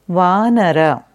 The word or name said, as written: Vanara